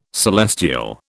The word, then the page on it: celestial: British English